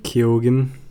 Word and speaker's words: Keoghan: Keoghan, I think his name is. – Is that how you say it? – Yeah.